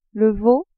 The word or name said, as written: veau